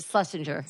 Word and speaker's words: Schlesinger: Schlesinger? – Eliza Schlesinger.